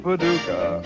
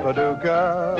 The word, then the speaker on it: Paducah: Tulip time, they get excited over tulip time, but I'm delighted over tulip time, because I happen to love a resident of Paducah. Paducah, if you want to, you can rhyme it with bazooka, but you can't poo-poo Paducah.